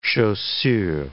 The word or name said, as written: chaussure